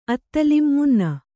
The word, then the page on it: ಅತ್ತಲಿಂ ಮುನ್ನ: Kannada